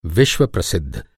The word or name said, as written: प्रसिद्ध